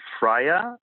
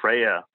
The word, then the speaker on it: Freyer: My name is Konstantin Freyer. That's how you pronounce it in German. The English say Freyer.